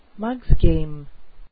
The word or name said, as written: mugs game